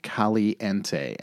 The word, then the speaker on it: Caliente: Pepper's Caliente. And I am saying that in the non-Spanish version because it is spelled C-A-L-I, like California, with the dash E-N-T-E.